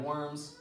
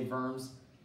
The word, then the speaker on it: Worms: We think of Martin Luther at the Diet of, I pronounce it the Diet of Worms because I'm not German, so I don't say Worms,